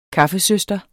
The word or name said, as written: kaffesøster